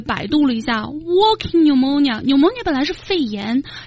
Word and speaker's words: pneumonia: 哎，大家注意肺炎这个单词啊，虽然平时我们用的少，但是你看它的读音很有意思，那个 P 打头的那个 P 呢，它是不发音的，直接读成 pneumonia。